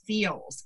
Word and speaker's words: Feals: And one of the things I want to thank, you know, Erin, our sponsor today, because we have this wonderful company and it's called Feels, and it's spelled F-E-A-L-S.